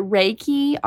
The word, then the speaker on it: Reiki: Is it Reiki, R-E-I-K-I? – Yep, you got it.